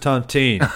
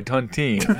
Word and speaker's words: tontine: He'll be the surviving member of the Tontaine that they were in, you see. – Okay. I believe it's pronounced Tontine. – Oh, I'm sorry, Tontine.